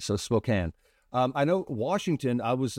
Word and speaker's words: Spokane: So Spokane is how it's properly pronounced.